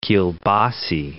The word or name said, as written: kielbasy